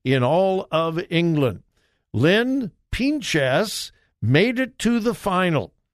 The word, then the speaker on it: Pinches: Now, you wouldn't think that a male had a distinct advantage over a female in pool, but Lynn Pinches, I have no idea how to really pronounce her name. It's Pinches, Pinches, P-I-N-C-H-E-S.